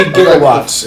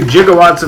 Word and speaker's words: gigawatt: This is the now obscure but once standard pronunciation of the word gigawatt. 1 billion watts. – Yeah. – Nowadays, it's usually pronounced with a hard G, as in Gander and GIF and Gold.